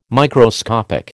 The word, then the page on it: Microscopic: American English